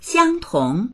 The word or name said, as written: xiang1tong2